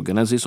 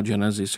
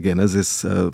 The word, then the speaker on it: Genesis: Pozor, je to Genesis, opravdu biblická kniha, ne třeba Genesis jako britská kapela, ale vlastně kdo chce, ať si to čte. – A tak to je anglická výslovnost.